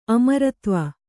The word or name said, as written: ಅಮರಾಧ್ವ